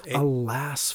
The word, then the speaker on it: Alas: And you know that when we talk about the lyrics and at least one of the lyrics that Jim changes for his, am I saying it right? Atlas Folklore? – Oh, no. Alas. – Alas Folklore. – Yeah. Okay. A-L-A-S.